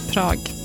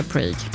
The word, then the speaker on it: Prag: Tydligen så uttalas Prag likadant på engelska som på svenska, typ. Svårt. Prag. Man vill ju säga Prague.